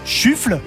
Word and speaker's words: Shuffle: Émission qui se nomme Shuffle, qu'il faut lire et prononcer en anglais, hein, shuffle, parce que sinon shuffle ça veut rien dire.